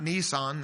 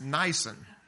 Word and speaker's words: Nisan: It's not Nissan, that's the car. It's Nisan.